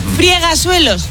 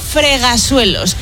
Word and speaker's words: fregasuelos: Friegasuelos, no se dice. Se dice fregasuelos.